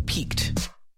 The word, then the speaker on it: piqued: Why is the curiosity of researchers, there I said it right, piqued? How do you spell that word? Piqued, P-I-Q-U-E-D?